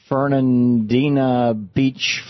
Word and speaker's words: Fernandina Beach: Dean in Fernandina Beach, Florida.